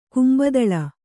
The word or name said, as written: ಕುಂಬತಳ